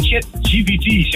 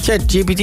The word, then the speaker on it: ChatGPT: Ik denk ChatGPT, zeg ik dat goed? – ChatGPT, laten we eens even kijken. Ja, je zei het wel goed, maar het antwoord is fout.